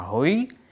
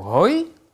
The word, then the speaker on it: hoj: Když to vyslovíte klesající intonací, to znamená hoj, tak je to jednotka, jako jeden předmět. Když to vyslovíte jako hoj, tak je to dvojka nebo mnoho předmětů.